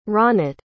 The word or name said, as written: ronat